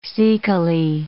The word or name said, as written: cecally